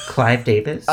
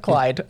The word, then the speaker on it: Clive: Did I just say his name right? – Clive Davis? – I said Clyde.